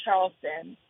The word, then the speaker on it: Charlestin: Charlestin, is that right? – Yes. But the American accent is Charleston. But I say it with the Haitian accent, yeah.